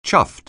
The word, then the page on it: chuffed: anglais